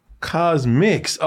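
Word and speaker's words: CosMc's: Cosmix.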